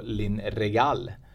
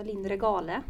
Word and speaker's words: Linn Regal: Hon heter ju nästan som Regalskeppet, tänker jag alltid på när jag tänker på Linn Regal. – Mm. – Uttalar jag det rätt då? – Det gör du. Mm. En av få, annars blir det ofta Linn Regale.